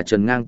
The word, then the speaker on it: Trần Ngang: Tên của hắn phát âm là Trần Ngang tiếng Trung ký âm, hắn là chính phủ vì ứng đối lưới trời nguy cơ tổ chức lên nhà khoa học chung một người.